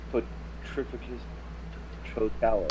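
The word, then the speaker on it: Petrificus Totalus: Potrificus, Protalus.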